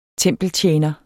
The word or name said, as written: tempeltjener